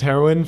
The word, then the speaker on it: heroine: That was Heroine from Heroine, as in the female version of Hero. I always end up saying heroin. – That's how it's pronounced.